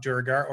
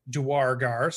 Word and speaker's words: Duergar: There's a Lore You Should Know video out, where they talk a little bit about Durgar, or he says the other pronunciation is Duargar. So I know some of you out there pronounce it in a couple of different ways. I like the Duargar pronunciation, is the way I've always done it.